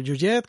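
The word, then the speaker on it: Huillet: Bueno, Straub eh, Juliet que se pronuncia así, ah, ¿no? – sí, creo que sí. – Bueno, realmente es Jean-Marie Straub y Daniel Yujet,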